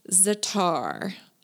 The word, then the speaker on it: za'atar: I have also been using a lot of za'atar, and I think I have the correct pronunciation on that. The spelling is Z-A apostrophe A-T-A-R.